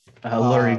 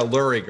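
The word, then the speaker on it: Leury: Lurie Garcia. – Yeah, Lurie Garcia.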